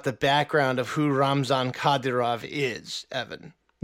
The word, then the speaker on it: Kadyrov: I believe it's pronounced Kadyrov. – Ramzan Kadyrov, yes.